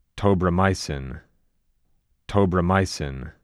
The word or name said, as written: Tobramycin